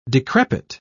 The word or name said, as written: decrepit